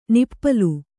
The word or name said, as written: ನಿಪ್ಪಲು